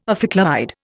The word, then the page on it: ophicleide: Indian